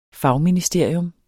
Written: fagministerium

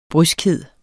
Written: bryskhed

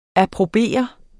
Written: APPROBERENDE

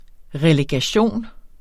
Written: relegation

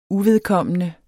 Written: uvedkommende

